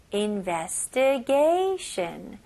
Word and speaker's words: investigation: Investigation. 똑같이 S-H-U-N 발음하시면 돼요. Investigation.